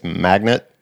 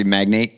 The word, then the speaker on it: magnate: Today it's magnet. Oil magnet? – I've heard it pronounced magnet. – Okay. – You would argue it should be magnate? – Yes, sir.